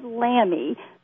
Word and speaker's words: Lammi: This is Todd Lammi.